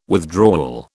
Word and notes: withdrawal: British English

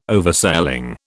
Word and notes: Oversailing: British English

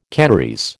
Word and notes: Canarese: American English

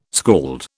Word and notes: skald: British English

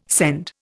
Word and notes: send: Indian

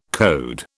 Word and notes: coed: British English

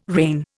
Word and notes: reign: Indian

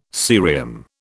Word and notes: cerium: British English

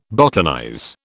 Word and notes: botanize: British English

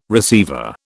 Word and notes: receiver: British English

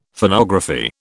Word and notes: Phonography: British English

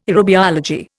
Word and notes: aerobiology: Indian

